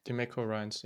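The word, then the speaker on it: DeMeco Ryans: Ähm, er war aber nicht der Einzige, der verpflichtet wurde, sondern es gab mit äh, die Mako, ich weiß aber nicht genau, spricht man ihn so aus, die Mako? Mhm. – Die Mako Ryans, ja. Genau,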